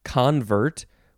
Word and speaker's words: convert: Second form, this one is pronounced convert, with the first syllable being emphasized.